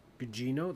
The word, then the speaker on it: Carla Gugino: Carla Gugino. – Yes. – Did I say that right? – Gugino? That's how I say it.